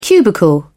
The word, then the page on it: cubicle: British English